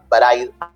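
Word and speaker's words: Braid: Otro juego indie muy piola eh, es Bride, se pronuncia Bride y se escribe Bride si lo quieren buscar.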